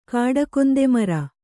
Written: ಕಾಡಕೊಂದೆಮರ

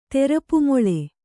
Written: ತೆರಪುಮೊಳೆ

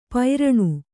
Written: ಪೈರಣು

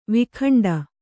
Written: ವೈಕುಂಠಿ

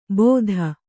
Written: ಬೋದ

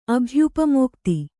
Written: ಅಭ್ಯುಪಮೋಕ್ತಿ

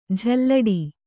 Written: ಜಲ್ಲಡೆ

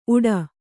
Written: ಊಟ

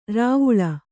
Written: ರೂವಿಲಿ

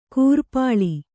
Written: ಕಿರಿಪಿಲ್ಲೆ

